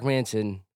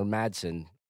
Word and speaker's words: Manson: It was Mark Manson, or Madsen. I think Manson is how you say it.